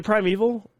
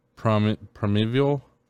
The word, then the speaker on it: primeval: Is it primeval? Is it prime? – Can I see it? – E-V-A-L. – Can I see it? Where is it at? Towards the top? – Paragraph. – Yeah, it's kind of... I would assume primeval.